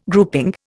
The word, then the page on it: grouping: Indian